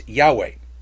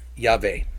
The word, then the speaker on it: Yahweh: Now, some pronounce Yud, He, Vav, He as Yahweh. There are some people who pronounce the tetragrammaton as Yehovah. For me, I pronounce it Yahweh.